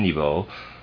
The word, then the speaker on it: niveau: The word I am pronouncing or trying to pronounce there is N-I-V-E-A-U. I'm pronouncing it niveau.